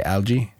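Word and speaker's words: Alge: How do you say, Algae? – Yeah. – Yeah.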